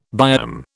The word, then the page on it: biome: British English